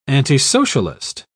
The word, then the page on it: antisocialist: British English